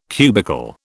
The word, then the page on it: cubical: British English